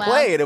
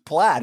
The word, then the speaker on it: plaid: And he was like, played. And we were like, that's pronounced plaid. He was like, no fucking way that's plaid.